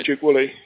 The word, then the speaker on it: Duke-Woolley: And you're perfectly right with the pronunciation, Robin Duke-Woolley.